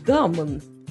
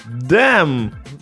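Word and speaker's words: damn: Ди, дамон. – Дэм, дурочка. – Написано дамон. – Ну, так читается дэм.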